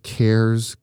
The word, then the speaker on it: Kehr: You did a great story about this company called Cares Candies. And it's not spelled like I care. – No. – It's spelled K-E-H-R.